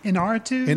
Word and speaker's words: Iñárritu: Alejandro González Iñárritu. Okay. Did I say that right? – Iñárritu.